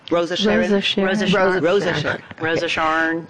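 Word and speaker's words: Rose of Sharon: No, how do you pronounce it? Rosh Hashan? – Rosa Sharon.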